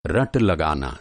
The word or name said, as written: रट